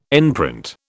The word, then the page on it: enprint: British English